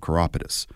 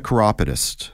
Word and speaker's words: chiropodist: Technically, both are correct. – Okay. – A chiropodist, that's more the British pronunciation. – Right. – So in Ontario, we tend to say chiropodist.